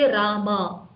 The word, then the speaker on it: Rama: And I heard even Prabhupada told, sometimes when people chant Hare Ramo, Hare Ramo, don't say Ramo, that's different. Say Rama, you know. So Prabhupada was so particular that Rama should not even be pronounced as Ramo. Hare Ramo, Hare Ramo. No, not Ramo, Rama, Hare Rama, you know.